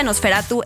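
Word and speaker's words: NOS4A2: sino con la serie que de hecho se escribe Nos 4A2 y digo, se pronuncia Nosferatu.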